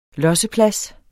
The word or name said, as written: LOSSEPLADSEN